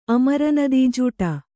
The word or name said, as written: ಅಮರನದೀಜೂಟ